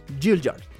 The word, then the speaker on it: Gaillard: Es Lomón, Lomón Gilliard, ¿no? Se escribe Gaillard, pero se pronuncia Gilliard.